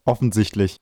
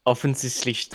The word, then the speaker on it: offensichtlich: The right pronunciation? – Offensichtlich. – Offensichtlich. – Yeah. – It's hard. – Yeah, you have to speak the S more hard.